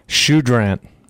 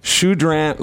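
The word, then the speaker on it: Choudrant: Shoudrant. – Shoudrant, Louisiana.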